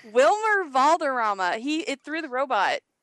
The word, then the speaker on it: Valderrama: DJ Cortez plays Seth, his brother, and Robert Patrick shows up in the role that Harvey Keitel was in the series, and Wilmer Valdemar Valderrama. I can never say that right.